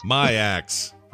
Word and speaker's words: Maax: Is it Ma-ax or Mayax? – Mayax. – My axe. – My axe. It's spelled M-A-X-X, but there's like some kind of pronunciation of it. – M-A-A-X. – That's two A's. Yeah, M-A-A-X.